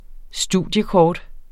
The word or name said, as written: studiekort